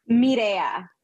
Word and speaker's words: Mireya: Mireya.